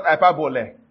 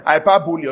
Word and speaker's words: hyperbole: Hyperbole, right? Not hyperbole. Hyperbole, although spelled like bole.